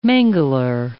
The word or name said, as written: mangler